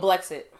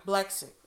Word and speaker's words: Blexit: The how do you pronounce it? Blix? – I don't know. – Just the group org saying they want black people to lead the Democratic Party. – Oh, Blexit. – Blexit.